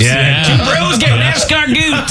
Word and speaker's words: escargot: So I have a buddy who, when we order escargot, he says, I'll take the escargot. – Goot, not even got. Like, I can see you saying got because that's how it's spelt. Escargot. – Sure. Yeah. – but escargot. – Goot.